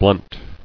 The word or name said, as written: Blunting